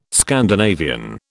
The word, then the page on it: Scandinavian: British English